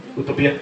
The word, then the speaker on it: Utopia: Matt Fraction, no sé si ustedes leyeron todo lo que estaba haciendo Matt Fraction, él es el creador de Utopia. Utopía, te lo dije mal, lo siento, soy un ignorante, gracias.